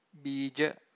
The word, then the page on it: beeja: Pali